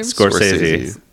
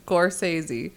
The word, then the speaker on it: Scorsese: How do you say his last name? – Scorsese. – Scorsese. – Yes.